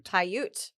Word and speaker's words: Paiute: Paiute.